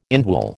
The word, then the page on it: indwell: American English